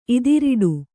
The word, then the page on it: ಇದಿರೆಡೆ: idireḍe